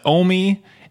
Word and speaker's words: Omi: According to Billboard's website, it's Omi, as in rhymes with foamy.